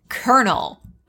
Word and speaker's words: colonel: The weirdest word is kernel because of how it's spelt. There is no R in that word. It's C-O-L-O-N-E-L.